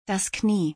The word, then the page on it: das Knie: German